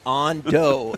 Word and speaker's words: Ando: Man, I knew somebody in high school named On Doe, and it was A-N-D-O.